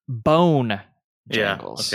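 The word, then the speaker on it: Bonejangles: So he plays a character, Bojangles. Well, no, I said that wrong. Let me. Bone Jangles.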